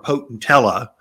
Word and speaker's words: potentilla: Number one seller, potentilla or potentella. If you're from the Midwest, potentilla, you don't pronounce double L's in Southwest country.